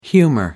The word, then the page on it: humor: American English